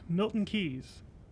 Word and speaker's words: Milton Keynes: Also like to thank Milton Keynes. Screw those guys. – Milton Keynes. – Well, screw those guys. – Did I say it wrong before? – No, you didn't. You said it right.